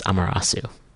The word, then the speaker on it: Amerasu: And it's, uh, if you spell, it's, uh, um, it's spelled A M E R A S U, um, and it's pronounced Amarasu.